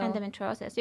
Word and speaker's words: endometriosis: Endo? – Endometriosis. You can do endo.